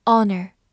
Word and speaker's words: honor: Honor，H O N O R，Honor，这个单词词首的 h 是不发音的，所以 o 直接发 o，n o r n er ner，honor honor。